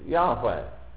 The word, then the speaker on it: Yahweh: which we believe is pronounced Yahweh, which 2 or 3 generations ago was generally pronounced Jehovah.